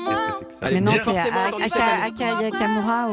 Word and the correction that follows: Aya Nakamura: Aka Nakamura. – Aka Nakamura. Aya Nakamura.